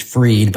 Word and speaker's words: Fried: So like my last name is Freed, but it's spelled Fried.